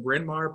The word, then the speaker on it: Bryn Mawr: It's in Bryn Mawr, Pennsylvania. Did I say that correctly? – That's right.